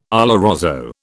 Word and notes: oloroso: British English

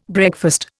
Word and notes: breakfast: Indian

